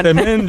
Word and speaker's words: Msemen: Es una palabra complicada, es M-S-E-M-E-N. Msemen, msemen, ¿vale? Y se pronuncia como lo ha hecho Hakima. Pero nunca, nunca se pronuncia como lo vamos a escuchar en boca de este tiktoker, porque puede ocasionar confusiones.